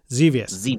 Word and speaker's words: Xevious: Xevious. Is that how you say it, or is it Xevious? – Xevious.